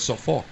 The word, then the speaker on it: Suffolk: Suffolk. – No, it's Suffolk. – The L is silent. – S-U-F-F-O-L-K.